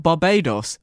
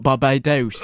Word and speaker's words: Barbados: Where they have just got back from, not Barbados, but Barbados. – Oh, God. It's really interesting because I have obviously both watched this episode yesterday separately and made separate notes. And my first note is pronunciation of Barbados. – Yeah, Barbados, which maybe that's a British-American thing.